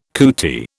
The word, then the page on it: cootie: British English